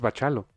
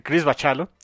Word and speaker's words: Bachalo: Bendis y Chris Bacalo. Que ya me enteré que es Bachalo. – Bachalo. – Dice el buen Beto Calvo que el nombre, como se pronuncia, es Chris Bachalo.